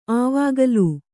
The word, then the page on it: ಆವಾಗಲೂ: āvāgalū